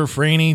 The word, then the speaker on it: Franey: My name is spelled Christopher Franey, just like it sounds, if that makes sense to your spellingness.